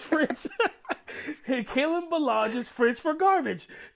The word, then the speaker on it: Ballage: Kalen Ballage is what does Ballage rhyme with? Garbage. So it's close.